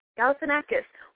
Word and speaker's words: Galifianakis: You have to start pronouncing his name, then sneeze, and then you're done. – Okay. Galifianakis.